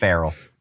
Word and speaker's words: Pharrell: Yeah, I believe it's pronounced Pharrell.